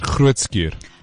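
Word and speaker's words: Groote Schuur: So they raise funds to reduce the waiting time for surgery by covering costs for additional theater time and nursing staff at Grootskuur. Did I say that right? – You did Grootskuur.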